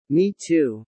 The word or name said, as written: Me too